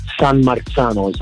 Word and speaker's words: San Marzano: San Marzano is how you pronounce it.